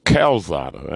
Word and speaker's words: Calzada: Lozada? I said that wrong. Actually, his name is Calzada. I had to, you know, I kind of had that wrong.